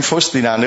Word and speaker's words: Faustina: vâng à, nữ thánh nữ thánh à, Vâng, một người đạo Phật mà biết thánh nữ Faustina quá tuyệt vời còn phát âm là đúng thánh Faustina nữa